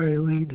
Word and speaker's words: Eileen: Oh, if it's not, can't find anything under my name, maybe it's under Eileen Hesse. – Okay, what's, will you spell that last, that first name? – E-I-L-E-E-N.